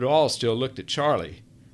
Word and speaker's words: Charlie: "Charlie," she said, emphasizing the second syllable, "I am so happy.